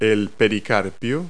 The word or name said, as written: pericarp